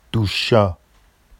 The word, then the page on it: duʃʃɑ: Vafsi